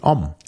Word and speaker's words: Homme: Nee, Om. – Om. – Mann. Om wie Om. Mann. Om. Okay. O-M einfach nur ausgesprochen. – Om. – Okay. – Das H ist rein etymologisch, wenn man es auf Lateinisch mal Homo mit H gesprochen hat, irgendwann mal ganz früher.